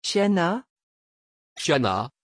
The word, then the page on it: chiana: French